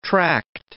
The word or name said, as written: tracked